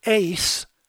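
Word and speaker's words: ace (εἰς): And the preposition ace, it's pronounced like it was A-C-E, but in Greek it's E-I-S, ace, meaning to or toward, to and touching.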